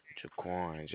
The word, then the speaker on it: Jaquan: Jaquan, J-A-Q-U-A-N. – Spell it. – J-A-Q-U-A-N.